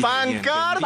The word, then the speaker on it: pancarta: Me imaginé la camparta. Uy, lo dije bien. Lo dije ¡Pancarta! Bien, ¡Pancarta!